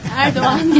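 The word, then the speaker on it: Erdoğan: I even said Erdogan. I didn't say Erdogan. – Erdogan, yes. The pronunciation is Erdogan. Since he don't have the G in English with a symbol on it.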